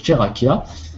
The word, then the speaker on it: Tcheraquiah: Donc ce code, le code du canal de création, je l'avais déjà donné, je crois, la dernière fois, c'est Cherakia. Ça s'écrit T-C-H-E-R-A-Q-U-I-A-H.